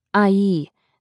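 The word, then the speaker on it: ayi: Pronounced IE, the word literally translates as auntie.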